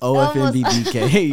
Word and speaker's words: Ofmb Dk: Almost. Ofmb Dk. You almost had it.